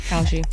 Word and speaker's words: Kalshi: Kalshi.